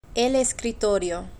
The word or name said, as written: el escritorio